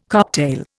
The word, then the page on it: cocktail: Indian